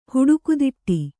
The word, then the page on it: ಹುಡುಕುದಿಟ್ಟಿ: Kannada